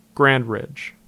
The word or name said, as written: Grand Ridge